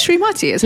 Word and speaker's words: Srimati: Is that Srimati? – Srimati. Thank you for saying it right. – Yes, aka Julie Pratt. – Pyatt. P-I-A-T-T, yeah.